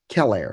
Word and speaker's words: Celer: C E L E R. Keller, if you want to say it that way.